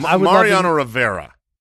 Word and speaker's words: Mariano Rivera: Mariano Rivera.